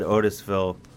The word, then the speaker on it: Otisville: Well, the Ramen Boys, a division of the Ramen Gang at Otisville Correctional...